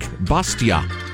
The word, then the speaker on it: Bastiat: So, the quote I was uh, paraphrasing yesterday about education comes from Frederic Bastiat. Ben Libertarian reminds me that because he is French, the last T in Bastiat is silent.